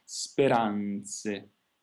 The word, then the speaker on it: speranze: E quindi anche in questo caso io indico sempre di cercare non neanche l'accento sulla A, bensì addirittura sulla N direttamente, pensare la direzione sulla N, speranze.